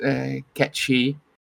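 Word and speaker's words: Cait Sith: And I'm like, I thought at this point people knew about at least the Gaelic tie-in, as why it's pronounced Ketshi.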